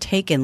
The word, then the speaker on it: Taycan: Gary says it's pronounced Taycan, like the movies.